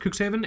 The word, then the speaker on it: Cuxhaven: I believe it's pronounced Cuxhaven, and then Bremerhaven, which are like, I guess, two towns in Germany.